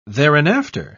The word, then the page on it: thereinafter: British English